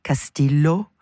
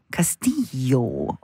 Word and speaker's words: Castillo: Am I saying that right, Kati? Castillo? No, Castillo. Hold on.